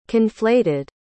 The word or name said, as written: conflated